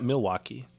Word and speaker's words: Milwaukee: Yeah, Milwaukee.